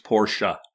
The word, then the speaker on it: Porsche: Like the family prefers Porsche. – Porsche. Okay. I just didn't see the A at the end of it. Well, E is pronounced A. – It's pronounced. It's not there, but it's pronounced.